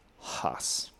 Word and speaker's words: Haas: It is pronounced Haas. – Yeah. – That's it. – Yeah, Haas would be H-A-S-S.